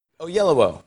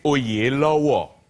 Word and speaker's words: Oyelowo: You're saying it correctly for Europe and America. My dad will watch this and be mortified because it's actually Oyelowo. Is how you say it.